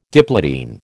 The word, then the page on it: diplotene: American English